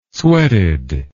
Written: sweated

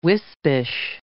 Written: wispish

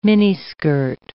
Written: miniskirt

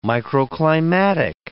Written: microclimatic

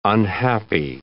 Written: unhappy

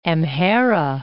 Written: Amhara

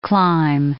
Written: clime

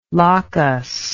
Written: lochus

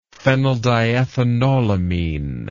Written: phenyldiethanolamine

